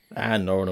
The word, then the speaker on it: Noren: Noren will do.